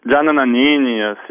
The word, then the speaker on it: Gianna Nannini: Est-ce que je l'ai bien dit alors, Gianna Nannini? – Gianna Nannini, ça se prononce, voilà. – Ouais, faut accentuer un petit peu, c'est ça. – Voilà, Gianna Nannini, voilà, c'est mieux comme ça.